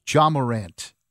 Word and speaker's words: Ja Morant: Ja Morant has said that he enjoys dunking on everybody.